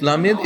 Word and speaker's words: Lamed: Lamed Rabotai is also, you see how I pronounced it? Many times people let the Lamed flow from the back of their tongue. Lamed. Yeah? The Lamed has to be a little bit sharper.